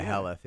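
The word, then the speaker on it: Haleth: Is that how you say it? – Yeah, it was just funny. Edain lady. Yeah, Helleth, yeah.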